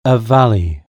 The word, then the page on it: valley: British English